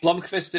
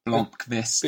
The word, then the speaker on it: Blomqvist: Blomqvist itself is like half a syllable too long. – Because we are best buds. It's the K into the V before, that I struggle with. Blomqvist.